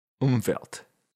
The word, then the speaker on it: Umwelt: This is very similar to a great word, which is Umwelt, German word, spelled with a W, but you pronounce it V.